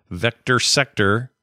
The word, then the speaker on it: VCTRSCTR: One of the new games on there is a vector mashup called Vector Sector, which is spelled VCTRSCTR.